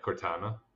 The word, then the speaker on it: Cortana: Mother Brain and Cortana coming. Am I saying it right? – Yeah, Cortana.